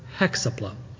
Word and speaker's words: Hexapla: I've heard it pronounced Hexapla. That's the first way I've ever heard it said. So please permit me that pronunciation of the word Hexapla. I think we're going to emphasize the six.